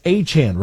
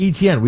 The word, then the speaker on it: Etienne: Achane. Remember, it's not Etienne. We've been saying his name wrong the whole time. It's actually Achane.